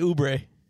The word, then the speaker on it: Oubre: Because Miles Bridges, Kelly Ogbonn Jr., LaMelo. – Do you say Ogbonn? – Ogbonn, how do you say his name? – Kelly Oubre.